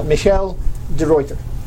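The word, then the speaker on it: Michiel de Ruyter: Michel de Ruyter.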